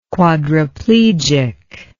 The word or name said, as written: quadraplegic